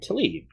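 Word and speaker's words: Taleb: There was a Twitter beef between Phil Tetlock and Nassim Tlaib.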